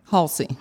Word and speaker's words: Haulcy: How do you pronounce your last name? – Haulcy. – Haulcy.